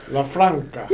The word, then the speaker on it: LaFranca: It was like, somebody said like LaFrancia. And I'm like, where's there an I? Like, there's no I in there. – LaFranca.